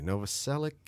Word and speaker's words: Novoselic: And we're drinking moonshine, and it's moonshine that he gets from Chris, I never pronounce his last name satisfactorily, Novoselic? – Yeah.